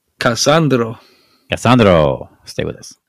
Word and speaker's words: Cassandro: I know inside you're dying at my pronunciations. – Just a Cassandro. Cassandro. Cassandro. Right.